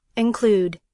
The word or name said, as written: include